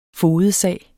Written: fogedsag

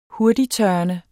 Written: hurtigttørrende

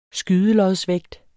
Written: skydelodsvægt

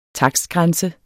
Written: takstgrænse

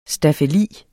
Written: staffeli

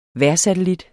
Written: vejrsatellit

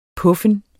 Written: puffen

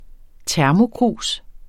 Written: termokrus

